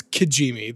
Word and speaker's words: Kijimi: We see Kojimi. I said it correctly this time, not Kojima. Kojimi. – No, Kojimi. You still said it wrong. It's Kijimi. There's no O in it.